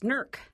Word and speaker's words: Newark: It's like it's like we pronounce the city of NEWARK in Ohio. It's NERK.